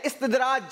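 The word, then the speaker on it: इस्तिदराज: सही प्रोनाउंसिएशन है इस्तेदराज।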